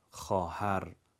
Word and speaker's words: خواهر: مثلا در زبان فارسی فرض کنید واژه خواهر واو اضافه نوشته شده اما خونده نمیشه، خواهر درستش هست.